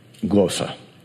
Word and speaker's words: glossa: Turn to Acts chapter 2, and what we're going to see is that this word tongue or tongues is pronounced in the Greek glossa. It's G-L-O-S-S-A.